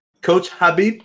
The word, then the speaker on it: Khabib: Coach Habib. K is silent.